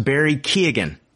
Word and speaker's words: Keoghan: And it is pronounced Barry Keoghan. You pronounce the G.